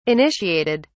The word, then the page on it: initiated: British English